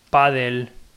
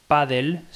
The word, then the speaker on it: Paddle: Paddle se escribe Padle, pero se pronuncia Paddle. Otra vez volvemos a invertir la E y la L.